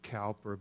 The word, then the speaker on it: Cowper: His last name is spelled C-O-W-P-E-R. We pronounce it Cowper, but it really was William Cowper.